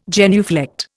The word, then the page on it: genuflect: Indian